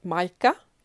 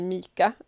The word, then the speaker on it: mica: Men det finns något som heter Maika, eller Mika, det stavas M-I-C-A.